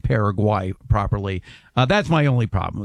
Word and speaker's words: Paraguay: I correctly pronounce it Paraguay. It's Paraguay, not Paraguay.